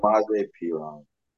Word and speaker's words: Samaje Perine: Samanya Perine, how do you say his name? He was excellent. – Samaje Perine. – Yeah, Perine.